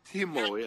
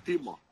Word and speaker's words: Timo: Timo, yeah. In Finnish language, you always pronounce exactly as you write the words. – No. See, over here, it's spelled Timo. Yeah. – Timo, exactly.